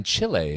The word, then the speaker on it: Chile: She's with Team Canada in the three-on-three version of Team Canada down in Chile. And you said it correctly there.